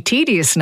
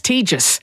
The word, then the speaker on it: tedious: But I don't know, a word like tedious, that we say tedious now, that was once pronounced tejus. And that was the recommended pronunciation.